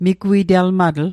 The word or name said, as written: migwite'lmatl